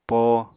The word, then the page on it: poo: Apatani